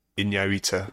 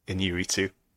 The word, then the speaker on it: Iñárritu: Iñárritu. Yeah. Iñárritu. – Iñárritu. – Iñárritu. – Iñárritu. – Iñárritu. – Good.